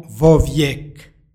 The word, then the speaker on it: vo vijek: Imenica vijek počinje istim glasom kao i predlog ispred nje. Radi lakšeg izgovora između njih se dodaje, odnosno umeće izgovorno o. Stoga izgovaramo vo vijek.